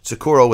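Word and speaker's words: Tsukuro: Sakuro with a silent T at the beginning.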